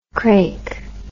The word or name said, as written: craik